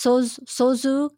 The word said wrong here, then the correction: Suzhou: Garden in China? – Suzhou? Yeah, the Suzhou Garden.